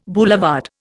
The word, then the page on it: boulevard: Indian